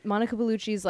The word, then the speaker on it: Monica Bellucci: Monica Bellucci. Yeah. – And it just came out of my mouth and I was like, that sounded wrong. I guess I was right. Monica Bellucci's like full, what do you call it?